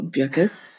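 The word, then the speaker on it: Beukes: I read somewhere that she always tells people to pronounce her surname like it rhymes with mucus, so Bierkes.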